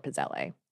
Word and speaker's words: pizzelle: I'm nearly positive that in the Italian it should be pizzelle, but I understand that Americans pronounce it both pizzel or pizzelle.